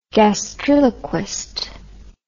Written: gastriloquist

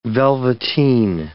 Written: velveteen

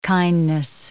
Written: kindness

